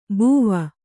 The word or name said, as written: ಬುವಾ